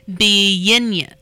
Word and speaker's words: Bienna: It has a pronunciation on the back, actually. Oh, okay. Bienna. Bienna? Yeah, I think you said Bienna. – Bienna. – It's just the way it's phonetically. – So like B-N, good. – Yeah, B-N-A.